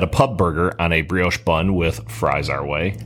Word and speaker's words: brioche: The Hilltopper, Angus patty topped with bacon and crumbled blue cheese on a brioche bun.